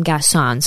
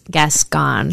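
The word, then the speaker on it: Gascón: Also, side note, I did an episode on this before we introduced Allie to the show, and I called him Gascon. So if you're confused, I said it wrong. It's Gascon.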